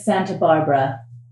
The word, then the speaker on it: Santa Barbara: And my last name is Santa Barbara. – Can you spell that for me, please? – Sure. S-A-N-T-A, like Santa himself.